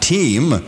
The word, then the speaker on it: WTEM: That, by the way, will be pronounced TEAM as the station takes on a new all-sports format.